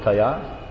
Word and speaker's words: Kaya: Kaya? K-A-Y long A. Kaya.